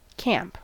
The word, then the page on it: camp: American English